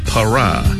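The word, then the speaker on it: Parah: Para, Para, P-A-R-A-H예요.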